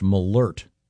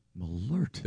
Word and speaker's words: Malort: And this says it's pronounced Malort. – Malort. – There's a, was it an umlaut, the two dots over the O? This has a pronouncer saying Malort.